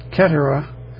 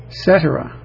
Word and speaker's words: Cetera: Not Cetera, not Ketera, Cetera. Not Ketera either. Peter Cetera is the way to pronounce his name.